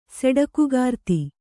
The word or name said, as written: ಸೆಡಕುಗಾರ್ತಿ